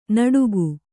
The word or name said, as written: ನಟುಕು